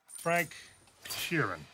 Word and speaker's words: Sheeran: Frank Sheeran. Am I saying that right? Yeah, you said right.